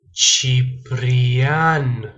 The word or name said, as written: ciprian